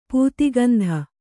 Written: ಪೂತಿಗಂಧಿ

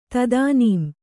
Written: ತದಾನೀಂ

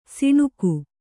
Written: ಸಾಣಿಗ

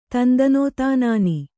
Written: ತಂದನೋತಾನಾನಿ

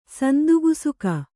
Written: ಸಂದಿಗುಸುಕ

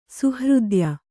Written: ಸುಹೃದಯ

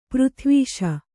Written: ಪ್ರತಿವಿಷ

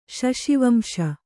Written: ಶಶಿವಂಶ

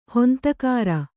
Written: ಹೊಂತಕಾರ

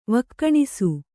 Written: ವೈಖಾನಸ

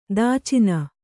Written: ದಾಚಿನ

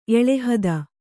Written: ಎಳೆಹದ

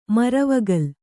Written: ಮರವಗಲ್

